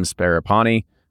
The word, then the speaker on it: Sparapani: Blue Run's founders, Mike Montgomery, Tim Sparapani, I said that right. He was on the show, and I was just like, make sure I said it right. Sparapani, Jesse McKnight, and Andy Brown will all remain with the brand.